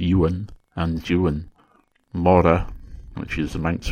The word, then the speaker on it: Juan: For example, the Manx form of John is Juan, which is spelt J U A N. And if you're not familiar with Manx pronunciation and spelling, you might think it was the Spanish name Juan, but it's not. It's Juan.